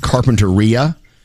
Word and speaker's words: Carpinteria: If you're from California, you know that little town is actually pronounced Carpinteria.